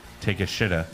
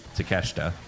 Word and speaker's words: Takeshita: I showed this name to a couple of my coworkers, and I was like, how would you pronounce it? They're like, Tekish Shida. – Yeah. – Yeah. – Well, that's how you pronounce it. – Well, no, it's Takeshita. – That is correct.